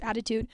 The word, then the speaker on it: ADDitude: I'm pretty sure that it came from Adhd-itude, which is pronounced attitude,